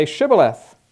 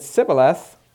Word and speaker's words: Shibboleth: If he answered no, they told him, "Please say Shibboleth." If he said Sibboleth, because he couldn't pronounce it correctly, they seized him and executed him at the fords of the Jordan.